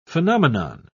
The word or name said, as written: phenomenon